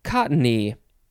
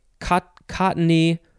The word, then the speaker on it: cottony: It is cottony. You can say cottony or cottony. Two syllables or three syllables. It's just the word cotton with a Y at the end.